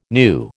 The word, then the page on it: gnu: American English